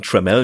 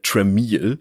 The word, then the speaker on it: Tramiel: Und bevor sich jetzt jemand darüber wundert, dass wir ihn Tramiel nennen, obwohl da doch ein I in seinem Namen steht, dieses I ist stumm. Und zeitlebens wird sein Name falsch ausgesprochen. Die Leute nennen ihn immer wieder Tramiel, was ihn offenbar so gestört hat, dass er nach seiner Zeit bei Commodore eine Firma gegründet hat namens Tramiel Technology ohne I im Namen, damit endlich mal die Leute seinen Namen richtig aussprechen.